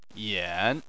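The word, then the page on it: yan: tone 3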